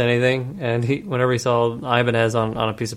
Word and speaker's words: Ibanez: he called it Ibonzi. – Ibonzi. I thought you were gonna say Ibanez, because that's how I've always heard it pronounced, like as a person's name.